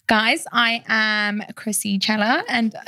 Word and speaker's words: Cela: Well, I'm so excited to have my guest on, Krissy Cela. – Cela. – What I knew I was gonna say that, and it even says pronounced Cela, like Coachella.